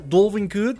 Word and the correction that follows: Dalvin Cook: ¿viste? ¿Viste? Mal como. ¿Cómo que mal como siempre? A ver cómo se pronuncia entonces. Ay, ay, lo hice igual. Dalvin. Dalvin Cook. Ahí está, para que veas.